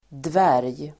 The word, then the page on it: dvärg: dvär:j